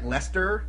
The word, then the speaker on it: Leicester: Just, freaking like Leicester, you know, like Leicester Square. There's like three extra letters that would make it Leicester, but they don't pronounce the Sess.